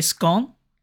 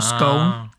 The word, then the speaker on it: scone: Cream tea means a scone or scone, depending how you pronounce it, scone or scone.